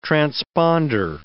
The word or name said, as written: transponder